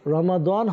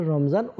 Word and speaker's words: রমজান: আরবিতে রমাদান হয় আর বাংলাতে আমরা এটাকে রমজান উচ্চারণ করি।